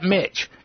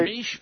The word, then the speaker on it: Mich: All right, spell Mish for us real way. – M-I-C-H. – Okay, we call that Mitch. – Yep, that's right. That's how Jaws would pronounce it.